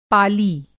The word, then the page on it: पाली: پالی